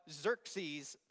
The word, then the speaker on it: Xerxes: This is Ahasuerus. That's the way the Hebrews pronounce his name. So the Jews that were living there at the time pronounce his name Ahasuerus, but Xerxes is how the Greeks pronounced it.